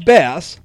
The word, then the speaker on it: Bass: Besides Bass, which rhymes with ass, the lawsuit names other high-level officials whom they claim were involved in pushing the curriculum onto students.